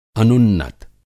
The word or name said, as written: अनुन्नत